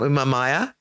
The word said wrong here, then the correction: Umamia: No, Umamia. – Umamia. Brilliant.